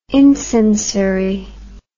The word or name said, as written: incensory